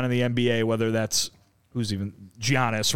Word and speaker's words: Giannis: Soft G. – But yeah. – Giannis.